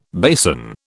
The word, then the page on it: basin: British English